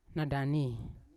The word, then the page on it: nadani: Yawa